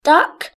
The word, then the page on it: duck: British English